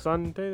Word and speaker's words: Missandei: Missandei, that's how you say it, right? – Yeah, sure.